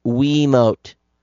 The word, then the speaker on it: Wiimote: Just wanted to point out, it's pronounced Wiimote.